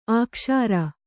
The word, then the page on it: ಅಕ್ಸರ: aksara